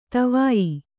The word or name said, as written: ದುವಯಿ